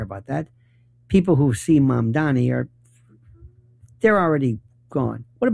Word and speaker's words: Mamdani: It's Mom, like Mom and Dad, Donnie, like Donnie Osmond, Mamdani.